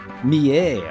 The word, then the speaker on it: Millet: Mie. – Oh. That's I was going to say Millet. – It's spelled like Millet.